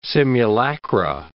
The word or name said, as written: simulacra